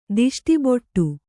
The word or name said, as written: ತುಷ್ಟಿಬಡು